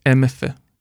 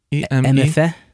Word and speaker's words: Emefe: How did you pronounce it? – MFE. – MFE? – Yeah, E-M-E-F-E.